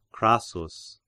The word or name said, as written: Crassus